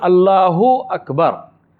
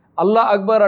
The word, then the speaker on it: Allahu Akbar: Allah Akbar. This is something which we do even in salah. The word is Allahu Akbar. Allah Akbar are two separate words.